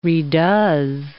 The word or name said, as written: redoes